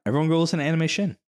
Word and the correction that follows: Anime Shun: Let me spell it because it's kind of weird. It's anime and then S-H-U-N. It's not... – Anime Shun. – Yes. – Anime Shun. Yeah.